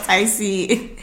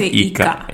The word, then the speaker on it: ICA: Don't go and say ICA. It's not ICA. I had a friend who used to say, oh, so I went to ICA. And I was like, oh my God, it's not ICA. – It's spelled ICA. But the Swedish alphabet, the I is... – It's E. – Yeah, so that's why it's called Ika. Yeah.